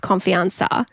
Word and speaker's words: confianza: And she told me that confidence in Spanish, pronounced confianza, translates to trust or self-trust.